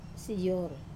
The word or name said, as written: sayor